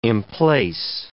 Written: emplace

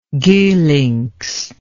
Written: Geulincx

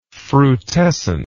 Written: frutescent

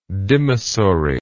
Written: dimissory

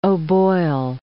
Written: O'Boyle

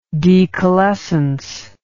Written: decalescence

